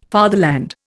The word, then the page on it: fatherland: Indian